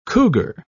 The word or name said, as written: cougar